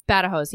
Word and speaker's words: Badajoz: And he was at the siege of, I don't even know how to pronounce, Badajoz, I want to say it is. – Yeah, Badajoz, yeah. – That's a real thing I studied.